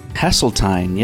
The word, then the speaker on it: Hasseltine: Hasseltine, yes, is the correct pronunciation, but I have been called so many different ways, and so I don't... You know, and called much worse. So I feel like I'm fine, however anyone pronounces it.